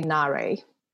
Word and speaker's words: Na-Rae: I usually say Na-Rae.